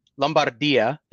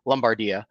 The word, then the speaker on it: Lombardia: Lombardia, there we go. There's the Italian pronunciation. Or if you're American, Lombardia.